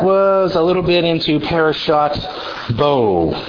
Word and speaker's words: parashat: Ashkenazis pronounce the Tav as an S sound, and Sephardic and modern Hebrew pronounce it as a T sound. So they say parashats, and they mean parashat. I'm just pronouncing it the way, if you were speaking in Israel today, that's how I'm speaking, or if you were in a Sephardic community.